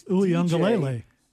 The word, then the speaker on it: Uiagalelei: It is Uiagalelei.